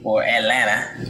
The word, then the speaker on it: Atlanta: So shout out to the Atlanta Atlanta. You don't pronounce the T.